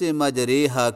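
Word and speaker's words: مجريها: چھٹا سبق، چند خصوصی الفاظ کی ادائیگی۔ نمبر ایک، لفظ مجریحہ کی را کو تجری کی را کی طرح نہ پڑھیں بلکہ قطرے کی را کی طرح جھکا کر پڑھیں، اس جھکانے کو امالہ کہتے ہیں۔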